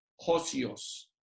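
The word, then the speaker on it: hosios (ὅσιος): Y es esta palabra que se más o menos se pronunciaría en español así, hosios.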